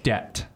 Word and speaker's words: debt: There's a sneaky silent letter in there. – I'm going to go with a T. – Well, it's not silent because you can hear the T. D-E-B-T.